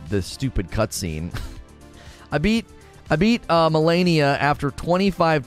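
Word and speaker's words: Malenia: It's pronounced Melania. Melania, great. Well, I just did an open and a recording with me saying Melania. Melania, Melania.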